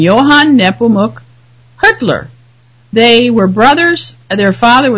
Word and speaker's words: Hüttler: And then the other is Hüttler, which it's Hüttler, but it's spelled H-U with an umlaut, T-T-L-E-R. And when it's said, it sounds a lot like Hitler.